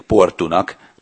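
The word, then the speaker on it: Porto: Érdekes egyébként, hogy ugye úgy írják a folyó nevét, hogy Tejó, és portugálul úgy ejtik, hogy Tejú, Portót pedig Portunak, tehát a szóvégi O helyett U ejtendő.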